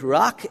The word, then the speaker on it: ruach: Spirit, the Hebrew word is ruach. I can't say it like a good Jewish person could, but the word is ruach, and it means wind or breath.